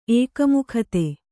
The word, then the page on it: ಏಕಮುಖತೆ: ēkamukhate